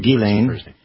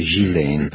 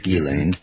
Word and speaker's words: Ghislaine: You know how to pronounce her first name? – Her name. Ghislaine. – Ghislaine, okay. – Ghislaine. – Good, yeah. – And some say Ghislaine, but it's Ghislaine.